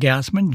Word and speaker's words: Gassman: Last name is spelled Gassman, G-A-S-S-M-A-N.